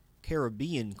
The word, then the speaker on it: Caribbean: Billy Ocean, Caribbean Queen, here on WFJA, W105.5, or Caribbean Queen, if you want to pronounce it that way.